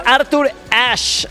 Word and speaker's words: Arthur Ashe: Oye, nos está escuchando nuestro querido amigo Ricardo Cázares, que me manda mensajito y nos dice: es Arthur Ashe, así se pronuncia, y es el estadio de Estados Unidos en New York, se llama así.